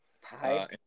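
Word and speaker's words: P.Y.: It's spelled P-Y, so pie, I guess. – Pie? – Pie, like life of.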